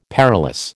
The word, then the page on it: perilous: American English